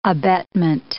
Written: abetment

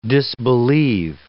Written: disbelief

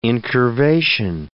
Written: incurvation

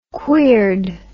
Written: queered

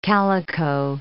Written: calico

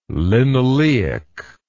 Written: linoleic

